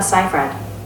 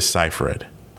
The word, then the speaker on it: Seyfried: The correct pronunciation of Amanda Seyfried is Amanda Seyfried. – Amanda Seyfried. So it's Amanda Seyfried.